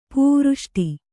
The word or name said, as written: ಪೂವೃಷ್ಟಿ